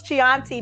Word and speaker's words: Chianti: Chianti.